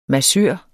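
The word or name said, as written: MASSØR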